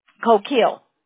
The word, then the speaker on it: Coquille: but it's Coquille High School, lives in North Salt Lake, Utah, and from, am I pronouncing that right? Coquille, Oregon? – Coquille.